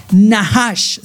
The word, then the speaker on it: nachash: Estas palabras son nahash, se escribe N-A-C-H-A-S-H. Nahash. Se, provea, se, pronuncia Nahash.